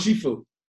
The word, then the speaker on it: Shifu: Shifu.